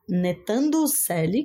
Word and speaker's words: Ntando Cele: Black Off, de Netando Selle, que se, gente, se pronuncia N-T-A-N-D-O-C-E-L-E.